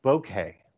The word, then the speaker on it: bokeh: It's called bokeh. – Bokeh. Is it bokeh? Nice. So yeah, the smaller the better. – B-O-K-E-H, I believe is how it's spelled.